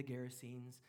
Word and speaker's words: Gerasenes: They arrive on the shores of, it's pronounced lots of different ways. The English pronunciation would be the Gerasenes, but you could pronounce it other ways if you wish.